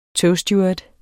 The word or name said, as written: togsteward